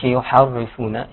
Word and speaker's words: يحرفون: يحرفون، الراء هنا مرققه. – تمام ما يقولش يحرفون، يحري.